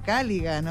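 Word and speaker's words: Culligan: Refresca tu verano con los dispensadores de agua purificada Caligan. Olvídate del plástico y disfruta del agua que amas en todo Chile. Visítalos en caligan.cl y pide tu prueba gratis. Caligan se escribe Cullign.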